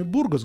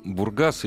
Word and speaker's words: Бургас: Э, Бургас или Бургас, зависит от времени, когда это произносится. Сто лет назад Бургас, сейчас Бургас.